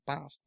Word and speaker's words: Bast: Bast, B-A-S-T. Yeah, Bast. I know that's how you spell it.